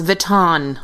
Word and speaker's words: Vuitton: Like kittens in Louis Vuitton mittens. – It's pronounced Vuitton. Are we all French? – Sam, you hit something.